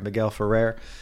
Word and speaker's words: Miguel Ferrer: Guy gets shot up, and that's where the junior executive, whose name is Morton, this is his last name, he's played by Miguel Ferreira, who's from Twin Peaks. I butchered his name probably. Miguel Ferrer.